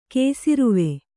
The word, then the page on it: ಕೇಸಿರುವೆ: kēsiruve